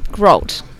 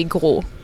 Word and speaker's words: Grault: And so how do you say your last name? – Grault. – Grault. Is it Grault? – Oh, if you said it in French, it would be Grault.